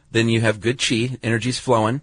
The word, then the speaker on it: qi: And that your body has an energy running through it called qi, even though it's spelled Q-I.